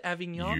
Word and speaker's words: Avignon: He fell off his bike and was taken to the nearby hospital in Avignon. I think I pronounced it Avignon.